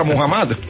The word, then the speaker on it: Mohamed: Y Susana Mohamed, ¿cómo se dice? ¿Mohamed o Mohamed? – Mohamed, eso sí, como usted. Póngalo a lo chipcha, Mohamed.